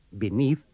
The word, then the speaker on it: beneath: In these dialogues, remember to pronounce the first E of below, beneath, beside, and between with the sound of the I in sit.